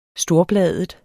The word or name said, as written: storbladet